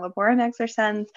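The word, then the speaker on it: Laborem Exercens: Human work in Laborum Exer. I'm going to say it wrong, Laborum Exercens.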